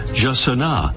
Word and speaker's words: jacaná: About those pronunciations, the Brazilian Portuguese way is Jacaná. Spanish versions include Hacana and Hassaná and Hassana.